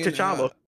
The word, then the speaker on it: T'Challa: How do you not have a T is silent when you watch Black Panther? – Because he say T'Challa.